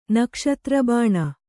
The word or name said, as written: ನಕ್ಷತ್ರಬಾಣ